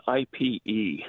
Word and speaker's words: ipe: Ipe, it's spelled IPE. – Oh. – It sounds more like Ipe.